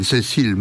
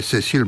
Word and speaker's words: Cécile: Alors je ne sais pas s'il faut prononcer à la française ou à l'anglaise. Alors, en français, ça donne Cécile McLaurin Salvant, et en anglais, Cécile McLaurin Salvant.